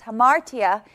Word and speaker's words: hamartia: And every single time this word is used, it's translated from the Greek word hamartia, which is pronounced hamartia, and it means to miss the mark or to wander from God's law.